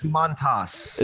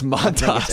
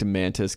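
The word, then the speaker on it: Mantas: It's Montas. – It's Montas. It's M-A-N-T-A-S. – Yeah, that's the... – Well, actually, you're there. – That's the proper pronunciation. I liked Mantis, Kalinatis, or whatever.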